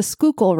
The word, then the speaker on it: Schuylkill: And it's Reading, not Reading. And it's the Schuylkill River, not any other weird pronunciation that you might have.